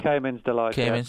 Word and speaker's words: Cayman's Delight: I don't know how you say it. It's Calmy's Delight or something like that? – Yeah, Cayman's Delight.